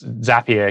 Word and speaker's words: Zapier: I got my mind blown when someone told me it's Zapier because it rhymes with happier.